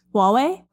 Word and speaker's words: Huawei: Huawei, is that how you say it? – Huawei? – Thank you. Wow, I was way off.